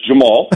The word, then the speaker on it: Jamahal: Glover Teixeira against Jamahai Hill. Jamahai Hill? I think I pronounced it right. – You did not. It's Jamal. Jamal Hill.